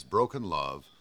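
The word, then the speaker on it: BRKN Love: It's Broken Love, and that's spelled BRKN.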